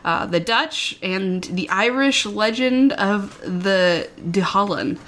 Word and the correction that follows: Dullahan: I'm sure James is listening and maybe correcting my pronunciation there, but... – Dullahan? – Dullahan? Oh, yeah. Dullahan.